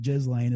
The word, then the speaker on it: Ghislaine: I know everybody pronounces it different, but I think Ghislaine is the best way.